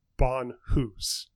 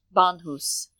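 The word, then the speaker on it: bānhūs: I believe it is pronounced Bonhus. Bonhus. Um, it says Bon Kofa, or actually, I didn't emphasize the first syllable.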